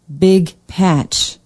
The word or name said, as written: big patch